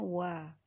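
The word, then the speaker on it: wa (は): Nah, baca dulu keterangannya di situ ada partikel wa, tulisannya hiragana ha tapi dibacanya wa merupakan menunjukkan bahwa kata sebelumnya adalah topik.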